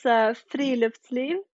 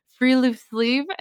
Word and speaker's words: friluftsliv: Yeah, it's friluftsliv. – Okay, I definitely can't say it like that. – Okay, so like friluftsliv. That's the Americanized pronunciation. – I know. I've been challenging myself here on the podcast lately because I had a couple episodes about hygge, and I'm like, I'm not saying that correctly. And now I'm trying to say this. So I'm like, well, this is rough. Okay, so free loop sleeve. – Yeah, that's really good.